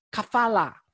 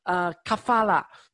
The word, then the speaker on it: kafala: 那么呢，其实它的原文呢是叫 kafala， kafala。主持人，你帮我发音一下 kafala。 – 卡发了。